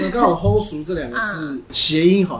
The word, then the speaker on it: 好嶼: 后熟这两个字其实是好语的台语发音。 – 哦，是啊，对，就是好是后，岛屿的语叫熟。